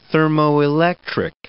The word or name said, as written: thermoelectric